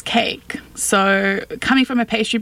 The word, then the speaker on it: koek: Cake. And I'm not sure if I'm pronouncing it correctly, K-O-E-K, cake. – You are.